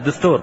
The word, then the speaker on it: دستور: دستور أو دستور، لا أدري النطق كيف، بالباء؟ – لا، بالدال. – بالدال، دستور. دستور.